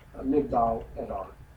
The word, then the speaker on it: Migdal Edar: Migdal Edar. M-I-G-D-A-L-E-D-A-R. M-I-G-D-A-L-E-D-A-R-E-D-E-R. It's spelled both ways. Migdal Edar. Migdal means tower. Edar means of the flock or sheep. – M-I-G-D-A-L.